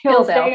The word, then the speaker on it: Hildale: It's Hildale.